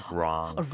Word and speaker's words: wrong: 좀 더 길게 동그랗게 wrong. – 동그랗게 하는 것도 뭐예요, 선생님? – wrong.